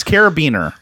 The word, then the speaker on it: carabiner: Yes, Carabiner. – Okay, Carabiner is the.